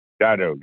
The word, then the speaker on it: Dai Do: Dado, if I say that correctly, sir, is that right? – Dido, yeah.